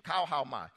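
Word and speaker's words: kauhaomai (καυχάομαι): All right, the word glory in this verse comes from the Greek word kauhaomai. It's actually the pronunciation, kauhaomai. Kauhaomai comes from the root word that means neck.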